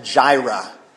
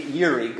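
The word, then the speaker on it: Yiri (יִרְאֶה): Yahweh Yiri, oftentimes in our English we pronounce it as Jireh, although the Hebrew is truly Yiri.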